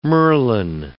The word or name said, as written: merlin